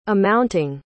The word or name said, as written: amounting